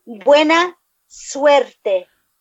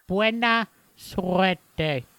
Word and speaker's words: buena suerte: You can roll your R's, right? – No, I can't. – Yuck, that's too many R's. – I got too many S's, too many R's. It's saliva. – How do you do it? Roll your R's. – Okay. – You got to have that buena, long.